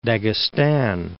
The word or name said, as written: Dagestan